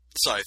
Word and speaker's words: scythe: And I think that's how it's pronounced, right? Scythe? – Scythe, yeah.